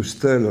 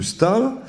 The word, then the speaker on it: Staël: j'explique partout que Madame de Stahl, STA, E, tréma, L, j'ai longtemps prononcé Madame de Stel à cause de ce E tréma, c'est Madame de Stal.